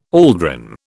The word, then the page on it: aldrin: British English